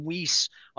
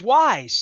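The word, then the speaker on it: Weis: And when I called them Weiss, I didn't even know how to pronounce it. But it's Wise.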